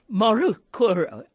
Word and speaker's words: Morukuru: There's the I want to pronounce this correctly. Makurukuru family de Hoop. doesn't trip off my tongue easily. Morukuru.